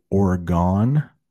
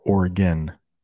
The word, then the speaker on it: Oregon: You might hear some people say Oregon or Oregon. It's got two pronunciations.